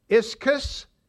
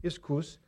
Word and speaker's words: ischus: It's ischus, I-S-C-H-U-S. Ischus, or ischus. If you want to pronounce it really Greek, iskous. And then energia, where we get the word energy from, E-N-E-R-G-I-A, energy, energia.